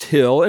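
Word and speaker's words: Khil: the performer is named Edward Hill. That's K-H-I-L. He was born in the former Soviet Union. Okay. Edward, it pronounced Hill, and his name is interesting.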